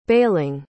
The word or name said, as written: bailing